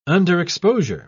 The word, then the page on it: underexposure: British English